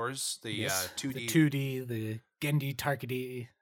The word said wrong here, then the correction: Tartakovsky: or yes. – Tartakovsky. – Tartakovsky. I never pronounce it right.